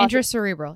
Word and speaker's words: intracerebral: Martin died at the age of 78 after meeting this family in his Manhattan apartment from an intracerebral hemorrhage. Did I say that right? basically he fell intracerebral.